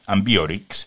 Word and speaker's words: Ambiorix: De vuelta a la Galia, concretó sus esfuerzos en la figura de Ambiorix, realmente no sé cómo se lee, tiene un acento en la I, Ambiorix,